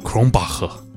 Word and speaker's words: Krombacher: Anyways，今天喝的是来自德国的啤酒，这个名字呢我刚刚去 Google 一下，用德文的发音应该是 Kronbacher Pils。